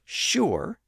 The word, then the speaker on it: sure: That's why the word sugar, spelled S-U-G-A-R, is pronounced with an initial sh sound today. And that's also why the word sure, S-U-R-E, is pronounced in a similar way with a sh sound at the front.